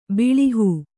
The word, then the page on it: ಬೆಳಹು: Kannada